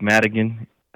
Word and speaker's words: Madigan: A lot of times when I do my little slogan is, don't get me Madigan, because you'll never forget my last name after that.